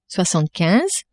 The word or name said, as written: soixantequinze